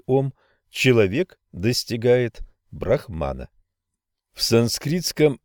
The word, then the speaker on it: ом: «А» произносится открытым горлом, «У» переносит звук вперед, а несколько назализированное, «М» произношение в нос, примечание редактора, подводит его к самим губам.